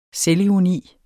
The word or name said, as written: selvironi